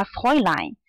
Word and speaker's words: Fräulein: 然后如果是女生的话呢，可以叫他 Fräulein。对，这个我拿这个笔点一下哈啊，Fräulein 啊，这个啊 umlaut 和 u 的它那个发音呢，就类似于像英语里面，对，我在这敲一下，它类似于像英语里面这个 boy 里面这个单词啊，Fräulein。那这个 Fräulein 这个词怎么来的呢？大家可能都知道这个词吧？